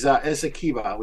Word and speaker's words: Essequibo: I mean, This is Essequibo, which is the area right there that's been annexed. And of course, you said it right, Jacob.